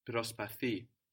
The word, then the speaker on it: προσπαθεί: But with the accent on the ending with this verb, no? Προσπαθεί.